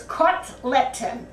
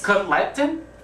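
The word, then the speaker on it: kotletan: Today's Yiddish word is kotleten. – Chagleken? – No, no. Just the way I pronounced it. Kotleten. let, 10. K-O-T, cut, L-E-T, let, A-N, cutleten. The Yiddish word for Bubby's hamburgers. – Cutleten? – Right, you finally did it.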